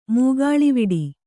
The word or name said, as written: ಮುಗುಳ್ವಡೆ